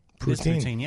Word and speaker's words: poutine: Is it poutine? – Poutine, yeah. – Okay, so I don't know what that is, really. – Poutine.